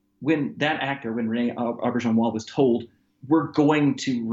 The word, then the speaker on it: Auberjonois: I read that and you're going to have to help me with his name, René Auberjonois.